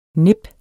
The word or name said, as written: nip